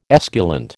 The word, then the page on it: esculent: American English